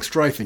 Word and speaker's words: Strieff: So that's Strieff, and that's how I found out how to pronounce his name.